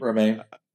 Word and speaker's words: Romijn: She, again, a beautiful, a great portrayal of Mystique by Rebecca, how you pronounce her name? – Romijn.